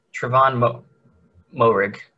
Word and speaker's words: Moehrig: Trevon Moehrig. – Trevon Moehrig. – Moehrig.